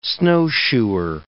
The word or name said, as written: snowshoer